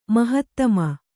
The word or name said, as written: ಮಹಾತ್ಮ